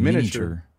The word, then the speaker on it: miniature: No, the I is silent. – Yeah, the miniature. I is silent, because I say miniature.